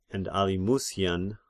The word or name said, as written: Alimusian